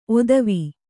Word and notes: ಓತ್ವ: ōtva

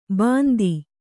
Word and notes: ಬಂದಿ: bandi

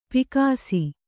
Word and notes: ಪೀಕಿಸು: pīkisu

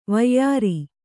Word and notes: ವೈಯಾರ: vaiyāra

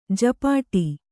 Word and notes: ಝಪಾಟಿ: jhapāṭi

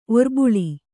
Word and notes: ಒರ್ಬುಳಿ: kannada

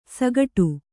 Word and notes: ಸಕಟ: Kannada

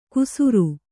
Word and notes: ಕುಸುರು: kusuru

